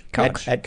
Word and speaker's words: Koch: Mayor, I think it was Mayor Coach, how do you say his name? I don't know. Whatever. – Koch.